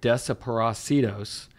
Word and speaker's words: Desaparecidos: He also has a side punk band called Despacito. I can never pronounce it right, Desaparecidos, yep.